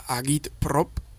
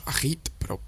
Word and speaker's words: agitprop: Y de agi y prop viene la palabra agitprop, que es como habría que decirla, pronunciando en ruso, pero aquí decimos agitprop, con J, casi.